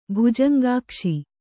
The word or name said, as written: ಭುಜಂಗಾಕ್ಷಿ